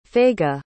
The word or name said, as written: Faiga